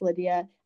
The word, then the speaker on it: LDIA: It's LDIA Official. Just pronounce Lydia.